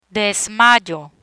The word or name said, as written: desmayo